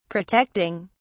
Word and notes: protecting: British English

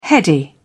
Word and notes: heady: British English

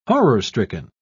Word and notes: horror-stricken: British English

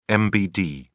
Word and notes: mbd: British English

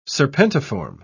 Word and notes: serpentiform: British English

